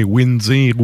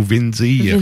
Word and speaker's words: Windir: Euh, dépendamment si vous le prononcez en V ou en W, mais Windir ou Vindir. – Vindir, que je prononce, moi.